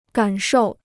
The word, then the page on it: 感受: gǎn shòu